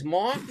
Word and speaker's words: Marc: And uh, your host is Marc with a C. That's me. Hi, everybody. – And Hello. that's how I spell it. When I say Marc with a C, that's how we spell it.